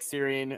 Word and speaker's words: Syring: Uh, I'm Nick Syring. Uh, last name is S Y R I N G. It, it's pronounced just like Syrian, like yeah, like your Syrian meat in a skillet or something like that.